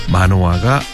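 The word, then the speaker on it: Manoah: 우리말로는 마노아 그리고 삼손입니다. 삼손 이야기 조금 전에 했죠. 얼마 전까지 그리고 지난주에 삼손이 죽었다고 그랬습니다. 자 마노아와 삼손도 부자지간입니다. 삼손이 아들이고 마노아가 아버지입니다.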